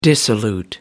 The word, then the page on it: dissolute: American English